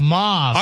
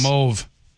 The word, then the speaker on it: mauve: The word M-A-U-V-E is pronounced mauve, rhyming with stove in British English, and more commonly mauve, rhyming with the first part of awful in American English.